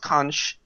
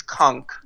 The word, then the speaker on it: conch: Which, guys, it's not pronounced conch; it's conch.